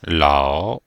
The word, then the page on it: lao3: pinyin